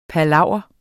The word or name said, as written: palaver